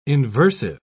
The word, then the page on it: inversive: British English